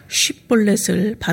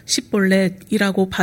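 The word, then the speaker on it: 쉽볼렛을 (שיבולת): 그래서 길러앗 사람들은 에브라임 사람들을 골라내기 위해서 요단강을 건너려는 사람들에게 강을 의미하는 단어 쉽볼렛을 발음하도록 하였습니다. SH를 S로 발음하여서 십볼렛이라고 발음하는 사람들은 죽임을 당했는데 그때 죽임당한 사람이 무려 4만 2천 명이나 되었습니다.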